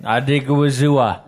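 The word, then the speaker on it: Odighizuwa: They also brought in backup level players like Odigizu. Fuck you, don't look at me like that. You say it. – Odigizua. – You pronounce the guy's name better.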